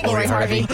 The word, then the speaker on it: Lori Harvey: Any one of these black celebrity males that come up, we could say dated Laura Harvey. – Lori Harvey, yes.